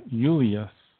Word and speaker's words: Julius: That's why James, John, Job, for example, Julius Caesar, the name was pronounced with a Y, Julius.